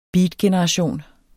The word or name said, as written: beatgeneration